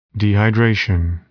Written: dehydration

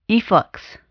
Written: efflux

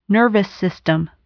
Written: nervous system

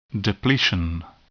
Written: depletion

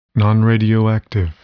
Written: nonradioactive

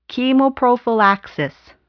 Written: chemoprophylaxis

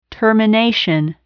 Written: termination

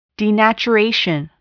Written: denaturation

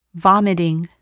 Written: vomiting

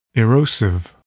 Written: erosive

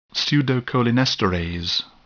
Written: pseudocholinesterase

